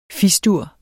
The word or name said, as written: Fis-dur